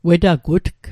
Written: wetagutg

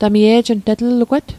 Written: e'jnt

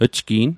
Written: jgi'n

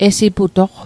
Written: esiputoq

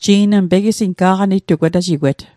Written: wetajigwet

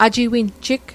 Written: ajiwinjig

